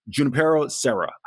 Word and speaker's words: Junipero Serra: Coming out of high school, he went to Junipero, and I was going to mispronounce this, but I believe it's pronounced Junipero Serra High School.